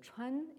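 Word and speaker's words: chuan (川): qui pris isolément se prononcent chuan et qui désignent un cours d'eau.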